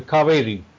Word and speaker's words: Kaveri: one of the rivers that you love is spelled Cauvery. – Yes, it's the Kaveri.